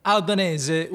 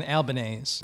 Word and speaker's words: Albanese: Albanese would be the Italian pronunciation, but it's been Americanized to rhyme with mayonnaise, as in Albanese.